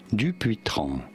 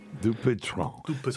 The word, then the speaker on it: Dupuytren: In French, in France, it is said as Dupuytren. – Dupuytren.